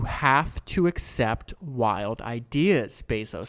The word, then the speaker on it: Bezos: I should know by now it's pronounced Bezos, but I fall back to the wrong pronunciation.